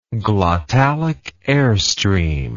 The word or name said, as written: glottalic airstream